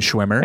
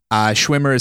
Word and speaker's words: Szwimer: If you need help finding it, go to Instagram and search my name, Jason Szwimer. – And we'll have all the links in the show notes and all that stuff as well. – Perfect. Szwimer is spelled S-Z-W-I-M, as in Motown, as in Mario, as in makeup, E-R.